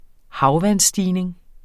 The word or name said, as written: havvandsstigning